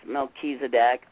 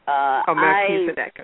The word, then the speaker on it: Melchizedek: I hope I'm pronouncing that correctly. – That is correct. It's Melchizedek. – Melchizedek, okay.